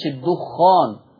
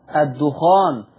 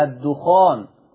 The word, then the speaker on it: الدخان: وبالمناسبة مش الدخان زي ما في ناس بنطقها غلط، الدخان بتخفيف الخاء، الدخان.